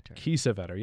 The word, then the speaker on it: Kiesewetter: Ethan Finley is a winger, even if, and I know you and I, is it Kiesewetter, I think is how you say it? – Kiesewetter.